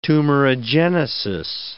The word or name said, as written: tumorigenesis